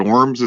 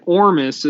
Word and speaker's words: ORMUS: Is it Orms? Is it Ormus? A lot of places online refer to it as Ormus, and it's monatomic gold. So how do you say it, and what is it? – You say it Ormus is how it's pronounced,